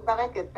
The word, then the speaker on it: Bereket: Yeah, Naomi Bereket, perfect.